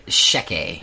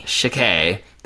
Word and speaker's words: Checquy: What was the pronunciation for this? Uh, again? Sheke. – Sheke. That's C H E C Q U Y.